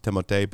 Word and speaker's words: Timothée: I've seen him in interviews, and they're like, what is your name, Timothée? Do we say Timothée? Or is it? And he's like, it's pronounced Timothée, but you can call me Arnold or Frank or Timothy, whatever.